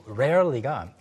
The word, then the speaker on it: rarely: 가끔씩 정말로 rarely가 그 나라 그 억양에 따라서 그 사람이 또 어느 나라 사람이냐에 따라서 really랑 좀 헷갈릴 때가 있어요.